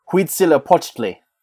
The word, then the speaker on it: Huitzilopochtli: Modern Nahual pronunciation. Let me hear that again. Huitzilopochtli.